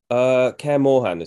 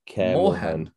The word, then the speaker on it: Kaer Morhen: Or is it more? How do you say it? – Kaer Morhen is what I say. Kaer Morhen.